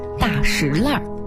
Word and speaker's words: 大栅栏: 比如说前门外的大石烂是极为著名的闹市，俗称大石烂。那为什么要叫大石烂呢，而不叫大栅栏？其实啊，经过考究，它的读音呢属于地名的特殊读音。历史悠久的大石烂读法呢属于保留古音的读法。